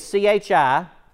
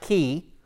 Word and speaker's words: Chi: Now, key is C H I, chi, but it's pronounced key and then the Rho.